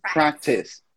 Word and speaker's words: practice: Okay, this one, we don't pronounce the final E, so it's just practice. Practice. – Practice. – Okay? Porque a gente tende a puxar o Izinho ali no final.